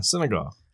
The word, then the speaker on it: Senegal: Yeah, Senegal.